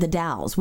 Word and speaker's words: The Dalles: From the Oregon Trail ends at the Dalles, which is D-A-L-L-E-S, like Dallas, but they don't pronounce the E for some reason.